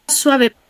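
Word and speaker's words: suave: スワベ。スワベ。 – スワベですね。このベ、ウーベの文字で書かれていますが、ベでも発音は同じですが、ここでは摩擦音になります。したがって、スワベではなく、スワベ。スワベではなく、スワベという発音です。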